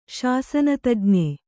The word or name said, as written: ಶಾಸನತಜ್ಞೆ